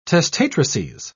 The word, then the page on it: testatrices: British English